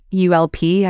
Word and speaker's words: ULP: In the book, the author Gay Hendricks talks about how some of his clients, they pronounce upper limit problem as ULP, like ULP, as in the word gulp, but without the G.